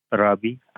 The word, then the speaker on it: Rabi: Robbie, R-A-B-I. – Robbie. Did I pronounce that correctly? – R-A-B-I is my first name.